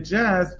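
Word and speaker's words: jazz: جزینگ یعنی همین موسیقی جاز رو، خب تلفظ انگلیسیش میگن جز.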